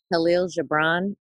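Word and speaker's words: Kahlil Gibran: Khalil Gibran, K-A-H-L-I-L, and then his last name is G-I-B-R-A-N.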